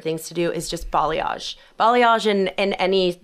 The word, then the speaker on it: balayage: It's so balayage. – Great. But balayage is, you know, how you say it. And basically balayage is a word. It's a French word.